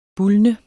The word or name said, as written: bulne